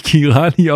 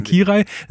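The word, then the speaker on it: Király: Also Kirali wird nicht, wird natürlich nicht Kirani ausgesprochen, sondern Kirai.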